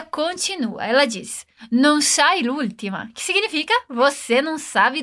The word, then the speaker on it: l'ultima: Perceba que a gente pronuncia tudo como se fosse uma palavra só. Mas, na verdade, temos o artigo la e a palavra última. O que acontece aqui é que a gente corta o A de la e emenda o L no última, l'ultima.